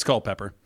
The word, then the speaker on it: Culpeper: It's Culpeper.